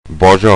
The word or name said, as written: Bozho